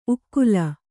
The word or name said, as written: ಉಕ್ಕಲು